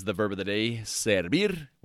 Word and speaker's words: servir: The verb of the day today is servir.